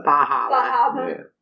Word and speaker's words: Bar Harbor: Right, the city I drove through to get to Acadia National Park over on the tip of Maine is called Bar Harbor, but if you're from anywhere around there, you don't pronounce any of those R's. – Bahaba.